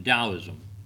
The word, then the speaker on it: Taoism: It's spelled with a T, but it's pronounced Taoism.